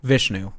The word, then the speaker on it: Vishnu: Vishnu? – Vishnu, yeah.